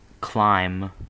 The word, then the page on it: clime: American English